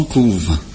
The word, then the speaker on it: couvent: Les poules du couvent couvent. Hmm. C'est une phrase tout à fait simple. Pourquoi on écrit pareil qu'on prononce pas pareil ouais,